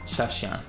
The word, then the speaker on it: Shashian: Shashian.